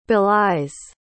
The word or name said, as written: belies